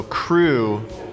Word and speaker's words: Creux: So Crew, but it's spelled like crew as you would say it in Cajun. Well, no, because crew in Cajun is C R E W E. Oh, this is C R E U X.